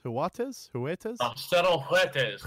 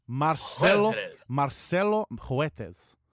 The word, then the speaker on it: Marcelo Huertas: The Rockets also, in a trade, another trade with the Lakers, have received, let me know if I'm pronouncing this incorrectly, Marcelo Huertas? – Marcelo Huertas. Come on. Give us a... – Marcelo Huertas.